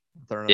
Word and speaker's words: Mathurin: Mathurin, or how do you pronounce that? – Mathurin. – Yeah. Mathurin. Yeah, him and Koloko, I think they're going to stay hot, and I think they'll topple Houston to advance to the Elite Eight.